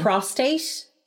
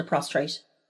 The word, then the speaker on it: prostate: Prostate. She called it a prostrate.